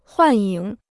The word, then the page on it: 幻影: huàn yǐng